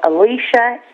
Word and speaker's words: Alesha: And my name is spelled Alesha, A-L-E-S-H-A.